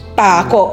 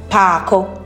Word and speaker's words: Pako: Pako. Oh, okay, so I didn't even say that properly. Pako.